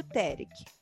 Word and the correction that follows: Derek: Eu acho que é isso que se pronuncia, né? Aquelas é que é brasileira. Eu é acho Derek, que é Derek. – Derek. É isso.